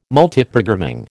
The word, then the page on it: Multiprogramming: American English